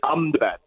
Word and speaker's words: Ahmedabad: Actually, they pronounce it here Ahmedabad, so they skip the E in the Ahmedabad bit and just go Ahmedabad.